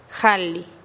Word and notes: jalih: TdVZ

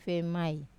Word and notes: vemai: Wamesa

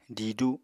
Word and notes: didu: Apatani

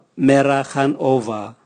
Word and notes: mera gan ova: Raga

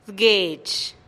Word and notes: d-gueex: TdVZ

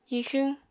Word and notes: hixwñ: Apatani

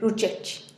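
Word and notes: ruchechih: Teotitlán del Valle Zapotec

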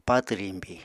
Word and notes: patw riñbi: Apatani

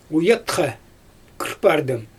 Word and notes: ujatxa kɯrpardɯm: Xyzyl

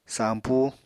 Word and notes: sampú: apatani

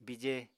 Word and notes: bije: Apatani

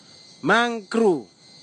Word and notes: magru: Kwamera

